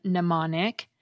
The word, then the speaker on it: mnemonic: The M is silent in mnemonic, but in the word amnesia, it gets pronounced as the end of the syllable M.